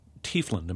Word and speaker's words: Tiefland: I mean, she was allowed to pursue this feature film she wanted to make, Tiefland.